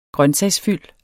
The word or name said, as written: grøntsagsfyld